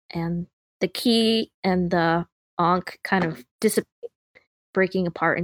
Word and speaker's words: ankh: Yeah, they are often carrying a ka, ankh, however you want to pronounce that. – An ankh, yeah. – Yeah, that's a symbol. Oh, let me double check. I can't spell. – A-N-K-H, an object or design resembling a cross but having a loop instead of the top arm.